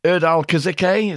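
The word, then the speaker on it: Erdal Kızılçay: So, tour incidents, mid-tour, Bowie, Erdal Kazike, is that how you pronounce it? – Yeah, yeah.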